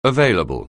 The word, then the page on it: available: anglais